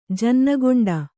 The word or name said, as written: ಜನ್ನಕೊಂಡ